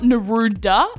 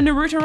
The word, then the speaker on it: Naruto: It's not Naruda. It's Naruta. – Naruta. – So it's N-A-R-U-T-O run, right? So this is just the first thing that's come up. Ready? So what's a Naruta run, you ask?